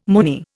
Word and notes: Moonie: Indian

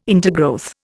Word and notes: intergrowth: Indian

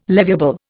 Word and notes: leviable: Indian

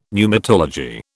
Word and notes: pneumatology: British English

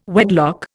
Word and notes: wedlock: Indian

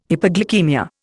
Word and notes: hypoglycaemia: Indian

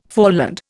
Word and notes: foreland: Indian

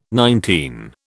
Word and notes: nineteen: British English

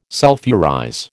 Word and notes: sulphurize: American English

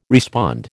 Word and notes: respond: American English

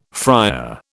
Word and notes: friar: British English

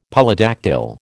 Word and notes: Polydactyl: American English

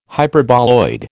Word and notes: Hyperboloid: American English

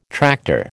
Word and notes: tractor: American English

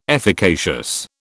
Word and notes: efficacious: British English

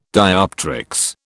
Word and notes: dioptrics: British English

